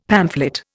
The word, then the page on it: pamphlet: Indian